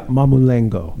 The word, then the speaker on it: Mamulengo: This is called, if I'm saying it right, Mamulenko. – Yeah, Mamulengo.